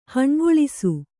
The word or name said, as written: ಹಣ್ಗೊಳಿಸು